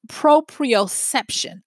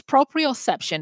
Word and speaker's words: proprioception: It's proprioception. I said that wrong. It's proprioception.